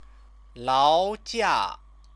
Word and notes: laojia: chinese

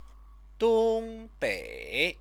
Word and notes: dongbei: chinese

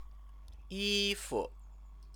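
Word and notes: 衣服: yīfu

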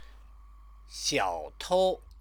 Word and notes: xiaotou: Chinese

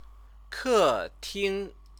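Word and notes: 客厅: kètīng

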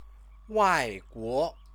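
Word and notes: 外国: wàiguó; Chinese

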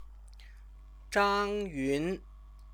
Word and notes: zhangyun: Chinese

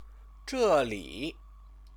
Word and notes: zheli: Chinese

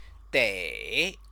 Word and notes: dei: chinese

